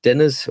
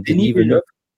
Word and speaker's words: Denis Villeneuve: Und jetzt die Denis, wird da Denis Villeneuve ausgesprochen eigentlich? Ist ja französisch, ja. Denis, ja. Nicht Dennis oder sondern Denis Villeneuve.